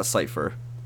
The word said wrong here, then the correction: Sypha: Is it Cypher or Sypha? Sypha. woman who does magic. Magic girl. – I think it's, like, pronounced Sypha.